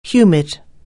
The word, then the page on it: humid: anglais